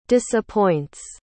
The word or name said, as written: disappoints